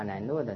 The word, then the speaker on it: 兰若: 好，接着遂隐居终南山丰德寺驻马兰若。啊，那么这个宣祖呢，啊，因为当时遇到这样的情形啊，就隐居在啊这个驻马兰若的这个地方。啊，那么随着兰若，兰若啊就是梵文的发音呢，啊，那么中文的意思呢就是啊寂静处，啊，这是比丘啊修行的地方。